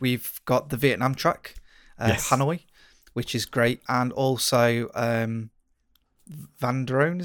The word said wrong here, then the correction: Zandvoort: is it? – I was going to let you tackle the pronunciation. Zandvoort. – That's it. Zandvoort. Yeah.